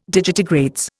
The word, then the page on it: digitigrades: Indian